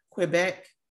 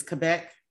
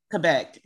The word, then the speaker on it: Quebec: Found out how to really pronounce Quebec and not Quebec. It's Quebec. Okay. So, really, I never knew that. – Teach us Quebec. – Quebec.